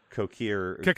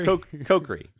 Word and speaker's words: Kokiri: How would you pronounce that? Kokiri. Kokiri. Yeah, Kokiri. That's it.